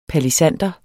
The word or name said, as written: palisander